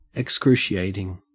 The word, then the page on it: excruciating: Australian English